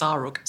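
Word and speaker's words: Serugh: I never know how you pronounce it. – Sarug. – Sarug.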